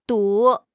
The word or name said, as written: du3